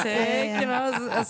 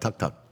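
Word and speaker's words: Taake: Tuck. Yeah, it's T-A-A-K-E. – Yeah, it's like Tuck. – I was like, Tick. – Some people say Tucky, which isn't right.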